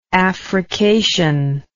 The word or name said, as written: affrication